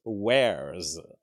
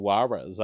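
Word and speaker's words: Warez: Joel says it rhymes with cares. Cares wears.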